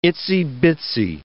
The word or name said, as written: itsy-bitsy